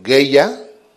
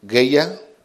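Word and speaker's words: Geya (גאולה): La otra es Geya, Geulla, Geya, con acento en la última A. Y la otra es Padá, P-A-D-A-H al final, Padá.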